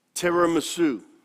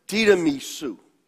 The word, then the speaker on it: tiramisu: So the title of my sermon is Tiramisu. In Italian, this would be correctly pronounced tiramisu. We Americanize it and we call it tiramisu or tiramisu.